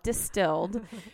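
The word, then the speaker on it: Dstld: It's called Distilled, but it's spelled without any vowels, which I know.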